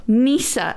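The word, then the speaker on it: Neser: Michael Nisa. It's a soft S.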